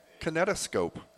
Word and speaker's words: kinetoscope: On this date, 1891, history of cinema, the first public display of Thomas Edison's prototype kinetoscope.